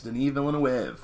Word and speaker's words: Denis Villeneuve: It was made pretty recently, 2016, and I'm going to try to pronounce this French-Canadian director's name. Denis Villeneuve? – Yes, Denis Villeneuve, one of my favorite modern directors.